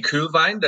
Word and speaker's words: Kuehlwein: My name's JP Kuehlwein. That's spelled with a U-E, just like Uber brands, and really goes beyond cool, as hopefully you'll note as we go along in this interview.